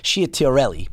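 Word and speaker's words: Ciattarelli: See, when you hear her say that, and then you know about what happened last night in the turn of events, and it's Schiaterelli, okay, Jack Schiaterelli, okay? That's how you pronounce it.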